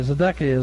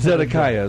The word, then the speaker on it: Zedekiah: Okay, Zedekiah is correct. Whatever you'd say is okay. he actually had him hurled into Zedekiah is what we say, yeah. – Okay, that's okay. You pronounce that. That's fine.